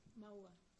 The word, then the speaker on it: Maua: Mawa. – Spell that. – M-A-U-A. – M-A-U-A.